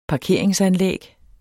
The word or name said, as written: parkering